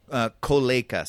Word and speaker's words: Kolejka: So, what's it called? Kolachi? – Kolejka. So it's Kolejka.